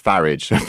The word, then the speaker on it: Farage: Well, by the way, our very own Allegra Stratton told me it rhymes with garage, so it's Farage. – Is it? So I've been pronouncing it wrong all these years.